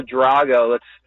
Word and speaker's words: Drago: Yeah, we'll go Drago.